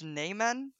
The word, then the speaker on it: namen: But the hashtag's pronounced "naymen" ah, usually, but we've yeah. switched it up because we, I don't know.